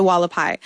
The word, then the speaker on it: Hualapai: The Hualapai.